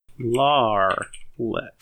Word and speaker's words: Larlitt: I'm surprised you've never heard of Larlet. – Larlet. – And it's spelled L-A-R-L-I-T-T, and you can roll a history check for me, as that is all Thunder Wreck will say.